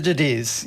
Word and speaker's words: Thucydides: Thucydides, did I pronounce Thucydides. – That right? – Thucydides. – Thucydides, yeah. Okay.